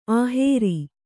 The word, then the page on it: ಆಹೇರಿ: āhēri